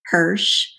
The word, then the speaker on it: Hersh: I do have a website that's simply my name, Sharon Hirsch, and Hirsch is spelled H-E-R-S-H. If you like chocolate, it's like Hershey.